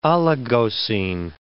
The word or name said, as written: Oligocene